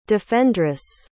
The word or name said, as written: defendress